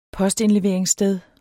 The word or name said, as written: postindleveringssted